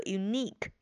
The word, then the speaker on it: unique: 再就是后边的这个 unique, 注意重音在中间的这个 e 上边，而这个 e 是长音的 e, unique。